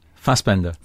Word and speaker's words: Fassbender: Because, like, you know, when you talk the way you're talking about Fassbinder, does he pronounce it Fassbender or Fassbinder? – Fassbender. – Fassbender.